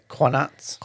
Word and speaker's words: qanat: Q-A-N-A-T. – Oh, yeah. Qanats.